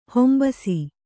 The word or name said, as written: ಹೊಂಬಸಿ